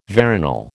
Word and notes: veronal: American English

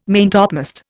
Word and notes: maintopmast: Indian

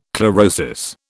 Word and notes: chlorosis: British English

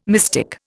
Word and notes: mystic: Indian

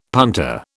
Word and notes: punter: British English

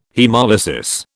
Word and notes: haemolysis: British English

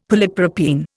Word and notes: polypropene: Indian

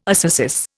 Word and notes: ascesis: Indian

